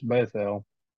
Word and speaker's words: Bethel: It's Bethel.